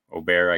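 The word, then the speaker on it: Åberg: It just shows that these guys waiting in the wings and in college, we've seen it with Aberg turning Aubert, I guess is the proper pronunciation, turning pro so quickly and winning.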